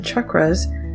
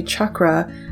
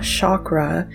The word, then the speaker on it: chakra: chakras. And the reason I say chakra and not chakra is some people reached out to let me know that the correct pronunciation is chakra,